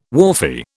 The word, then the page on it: wharfie: British English